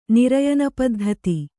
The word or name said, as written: ನಿರಯನಪದ್ಧತಿ